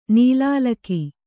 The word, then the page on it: ನೀಲಾಲಕಿ: nīlālaki